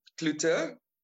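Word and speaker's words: Cloete: So it's Richie Clute. Can you pronounce my surname? – Clute. – There you go. So it's Richie Clute with a C, C-L-O-E-T-E.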